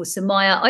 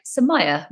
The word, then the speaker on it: Samaya: Today, I'm joined by the wonderful Samaya. I always remember it like Samaya rhymes with papaya. Samaya, that's how you introduced yourself to me when I was trying to work out just how I pronounce your name.